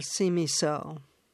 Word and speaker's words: Simiso: So tell us about your character's destiny and her mother, Simiso. How do I pronounce her mother's name? – You pronounced it perfectly. It's pronounced how it's spelled.